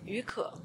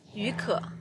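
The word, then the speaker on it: Yu Ke (雨可): Yu Ke. – Say it again. – Yu Ke.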